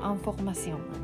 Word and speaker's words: information: にじゃあどうしてこのインフォメーションに対してのあの概念は違うんだろうっていうのをちょっと疑問に思ってあフランス語でもねインフォメーションって続きは全く一緒で発音がアンフォーマシオンなんですけどだからまあ同じもうインフォメーションのこの綴りですよ。